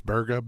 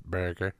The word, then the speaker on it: Burga: It's Burga, B-U-R-G-A, like a Boston person would pronounce Burga.